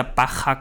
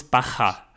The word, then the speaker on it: Baja: Weshalb zum Beispiel aus, aus der Baja California, der Bucht von Kalifornien, das ja mit J geschrieben wird und bei uns manchmal fälschlicherweise als Baja oder Baja ausgesprochen wird, wird dann plötzlich ein Baja obwohl es Bacher heißt.